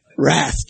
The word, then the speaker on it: wrath: You don't pronounce the W, do you? Wrath, 24 through 32.